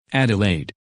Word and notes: Adelaide: English

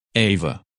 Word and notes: Ava: English

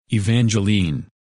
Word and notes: Evangeline: English

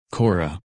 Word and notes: Cora: English

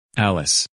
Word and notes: Alice: English

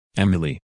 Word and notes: Emily: English

